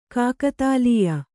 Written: ಕಾಕತಾಲೀಯ

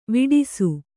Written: ವೇಡಿಸು

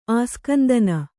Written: ಆಸ್ಕಂದನ

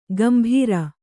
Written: ಗಂಭೀರ

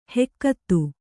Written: ಹುಗುತೆ

